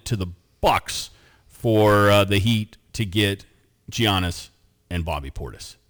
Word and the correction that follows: Giannis: That's I like that you pronounced the G. – Isn't that? Don't you need to do that? – No, it's a Y sound. – Are you sure? – Giannis.